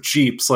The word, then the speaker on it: Jeep: Honestly, if you had showed, if you, at a pace that would induce an epileptic seizure, if you kept showing pictures of Jeeps, like one per nanosecond, and everyone had like a tiny subliminal message that just says Jeep rhymes with beep.